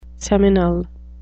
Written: seminal

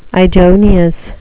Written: idoneous

